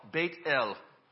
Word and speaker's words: Beit-El: So originally it would have been pronounced Be'el, Beit-El, which then, you know, over the years, Beit-El, Beth-El, you can see where we went with that.